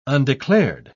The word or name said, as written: undeclared